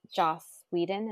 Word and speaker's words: Joss Whedon: And especially looking into Joss Whedon, is that how you say his name? – Yeah.